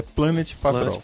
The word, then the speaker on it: Planet Patrol: Planet Patrol ou Planet Petrol. Eu falei errado. O nome correto é Planet Patrol.